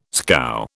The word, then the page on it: scow: British English